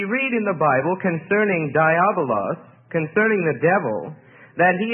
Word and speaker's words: diabolos: And in the Greek, it is diabolos, which is spelled D-I-A-B-O-L-O-S.